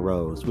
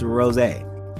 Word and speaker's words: Rosé: They say Rose. And I say, some of my friends are like, oh, you mean Rose? No, it's Rosé. That's how you pronounce the name. It's Rosé. That's her name.